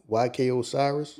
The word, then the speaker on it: YK Osiris: His name is YK Osiris. Know what I mean? So, that's if I'm saying his name right. – Yeah, you said it right.